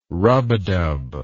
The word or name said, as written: rubadub